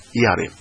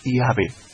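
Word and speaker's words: iabe (Ιαβε): escribe iabe, iota, alfa, beta, epsilon, iabe, como la mejor pronunciación fonética del tetragramatón, mucho antes que los rabinos que hemos dicho antes, los rabinos masoretas, metieran las vocales.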